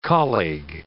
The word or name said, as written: colleague